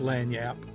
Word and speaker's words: Lagniappe: Lagniappe, pronounced as Lagniappe, is a Creole term that means an extra or unexpected gift.